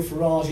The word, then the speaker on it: Farage: Eddie Mair joined Drive on LBC, of course, and Nigel Farage is on every evening. It is Farage, by the way, not Farage. He pronounces it to rhyme with the word garage, which is fitting, because most people's garages are also full of shit from the 1970s.